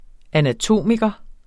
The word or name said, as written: anatom